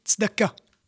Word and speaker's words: Tzedaka: D'accord Et en hébreu, on prononce plutôt Tzedaka. Hein, si on veut vraiment euh, bien prononcer.